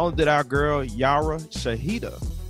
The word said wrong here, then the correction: Shahidi: did I say her name right? Yara Shahidi.